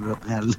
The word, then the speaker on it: L'Oréal: Está muy bien pronunciado L'Oréal.